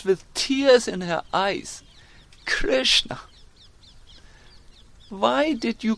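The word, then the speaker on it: Kṛṣṇā: That means the Supreme Lord Krishna met with Draupadi, who's also known as Krishna, with a long A.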